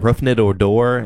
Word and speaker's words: Rougned Odor: Jose Bautista was Blue Jays Rangers and Rougned Odor is how you pronounce it.